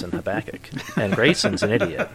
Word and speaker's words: Habakkuk: So it's Amos and then Habakkuk. – That's the correct pronunciation? – Yep.